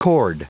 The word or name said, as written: cord